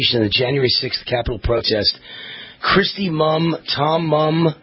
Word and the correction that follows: Munn: Dawn Munn, excuse me, M-U-N-N, Munn, pronouncing it wrong here, Munn,